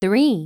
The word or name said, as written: 3